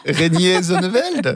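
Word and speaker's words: Reinier Zonneveld: Oh mince, comment est-ce qu'on prononce ça ? Rainer Zonneveld ? – Ouais, un truc du genre. – Rainier Zonneveld ? – Perfect.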